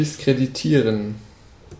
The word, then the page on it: discredit: American English; [dɪs.ˈkɹɛd.ɪt]